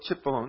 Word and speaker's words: Cipollone: This is a Stevens opinion that follows up on Cipollone or Cipollone. I never know how to say it. How do you say it? – Cipollone.